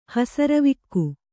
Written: ಹಸರವಿಕ್ಕು

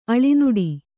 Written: ಅಳಿನುಡಿ

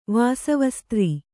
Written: ವಾಸವಸ್ತ್ರೀ

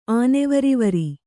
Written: ಆನೆವರಿವರಿ

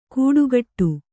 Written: ಗೂಡುಕಟ್ಟು

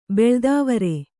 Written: ಬಿಳಿದಾವರೆ

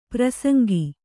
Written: ಪ್ರಸಂಗಿ

